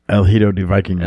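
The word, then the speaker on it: El Hijo del Vikingo: How you say it? El Vikingo del Hijo. – El Hijo de Vikingo.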